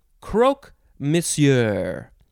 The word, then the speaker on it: croque monsieur: You could pronounce it croque or croc or croque. Croc, but see, I don't know, croc, croc, croque. And then you could say monsieur or messieur, if you want to say it that way. It is spelled C-R-O-Q-U-E, second word, M-O-N-S-I-E-U-R, croque monsieur, noun from 1915.